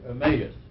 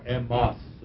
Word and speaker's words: Emmaus: Emmaus, or Emmaus, some might pronounce it.